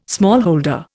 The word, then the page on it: smallholder: Indian